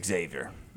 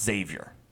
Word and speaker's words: Xavier: Texas looked really good against Xavier. Actually, it's pronounced Xavier.